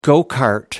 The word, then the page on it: go-kart: American English